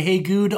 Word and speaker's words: Heygude: I go by Hey Good on the internet. H-E-Y-G-U-D-E.